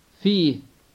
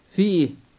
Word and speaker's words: في: It is like the word في meaning in, except that the E sound is longer, and sometimes it is pronounced as if it has a slight H at the end.